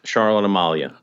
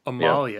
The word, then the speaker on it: Charlotte Amalie: Did I pronounce the name of the harbor correctly? – Charlotte Amalie. – Amalie. Yeah. – Yes.